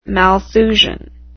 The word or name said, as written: Malthusian